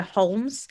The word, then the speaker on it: Holmes: Yeah, my mum pronounces the L in that word. She calls her Holmes.